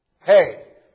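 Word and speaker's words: He: This stanza of Psalm 119, entitled H-E, pronounced Hey,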